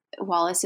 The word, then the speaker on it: Wallace: And Wallace is spelled W-A-L-L-A-C-E.